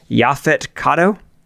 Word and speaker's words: Yaphet Kotto: Now, is this how you pronounce it? Yaphet Kotto? – Sounds good to me.